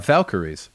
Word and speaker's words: Valkyries: let's move on with this flight of the Valrakies. All right, uh, Valkyries.